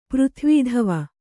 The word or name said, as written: ಪೃಥ್ವೀಧವ